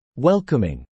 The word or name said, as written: welcoming